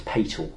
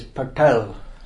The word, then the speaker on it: Patel: I remember being very young and being convinced it was pronounced Patel. But there we go, that's... – Actually, it's Patel. In English, you can't pronounce it properly.